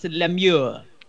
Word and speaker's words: Lemieux: And normally, when you see that EUX, it's Lemieux. Pronounced the way you were saying Lemieux. It, like last week. Yeah, like it's Lemieux.